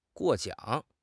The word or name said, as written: 过奖